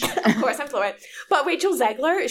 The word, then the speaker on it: Rachel Zegler: So it's Rachel Zegler.